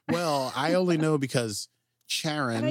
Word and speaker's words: Charon: they call it the Charon. It's a hard C. I wrote it down, but I've always pronounced that Charon.